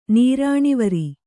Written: ನೀರಾಣಿವರಿ

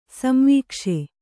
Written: ಸಂವೀಕ್ಷೆ

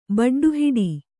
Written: ಬಡ್ಡುಹಿಡಿ

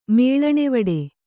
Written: ಮೇಳಣೆವಡೆ

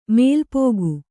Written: ಮೆಲ್ಪೊಗೆ

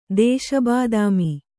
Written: ದೇಶಬಾದಾಮಿ

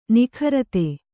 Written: ನಿಕೃತ

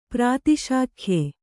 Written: ಪ್ರಾತಿಶಾಖ್ಯೆ

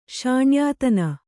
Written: ಶಾಣ್ಯಾತನ

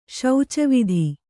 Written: ಶೌಚವಿಧಿ

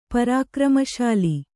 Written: ಪರಾಕ್ರಮಶಾಲಿ

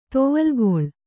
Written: ತೊವಲ್ಗೊಳ್

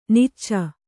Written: ನಾಚ್

